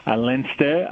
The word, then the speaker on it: Leinster: Leinster, oh, I always say this wrong, apologies. Leinster. – No, you said it correct.